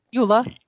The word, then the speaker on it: Eula: E-U-L-A? How do you say that? – Euler.